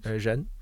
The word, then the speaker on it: Ren: Well, it looks like Ren, doesn't it? It's R-E-N. – It does look like Ren. – But how do you pronounce that in Chinese? – Zhen. – Zhen. There we go.